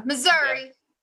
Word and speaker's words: Missouri: Shouldn't it be like Missouri? Or something like that. How do you pronounce Missouri? Sometimes, yeah. Missouri. – Okay. So they don't say Missouri? – No. Southerners say Missouri.